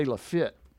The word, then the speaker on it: Lafayette: In Alabama, they say Lafitte. – Oh, no, we say Lafayette here because we have a Lafayette High and we're Fayette County.